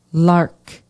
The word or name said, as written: Lark